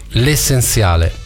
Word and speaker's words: L'essenziale: Brano pubblicato il 14 febbraio del 2013, L'essenziale. Ho detto bene con la Z? – Benissimo, guarda.